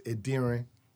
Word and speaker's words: Adiran: it's Adiran. That's how it's actually said. Adiran. – Adiran. – Adiran. Yeah, that's how it's said. It's not easy. – Mm-hmm. – Even like I said, even Nigerians don't. – You kind of emphasize an N at the end.